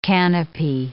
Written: canape

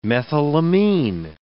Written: methylamine

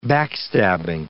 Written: backstabbing

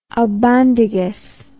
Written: albondigas

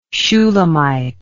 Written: Shulamite

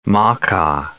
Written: Makah

